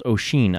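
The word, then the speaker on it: Oisín: Oisin is Finn's son and a poet who narrates the tales of his father, the Fenian cycle, or the Ossianic cycle. Oh, it's pronounced Oisin.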